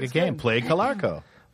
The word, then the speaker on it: Calarco: How do you spell that last name? C-L-A-R. Clarko? – C-A-L-A-R-C-O. – Clarko. – Colarco. – Yeah.